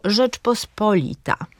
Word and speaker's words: Rzeczpospolita: I ostatnie przykłady takiego akcentu na trzecią sylabę to pojedyncze rzeczowniki obcego pochodzenia. Na przykład właśnie mówimy prezydent, albo Rzeczpospolita, albo uniwersytet, czy maksimum i minimum.